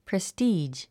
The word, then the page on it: prestige: prestíːʒ; プレスティージ